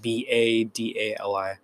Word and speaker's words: Badali: My last name is spelled Badali.